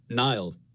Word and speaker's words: Niall: When I was growing up, I would say Nile, like N-I-L-E effectively, like the river.